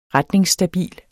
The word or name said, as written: retningsstabil